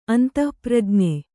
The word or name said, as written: ಅಂತಃಪ್ರಜ್ಞೆ